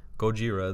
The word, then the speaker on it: Gojira: Gojira, the Japanese pronunciation, G-O-J-I-R-A.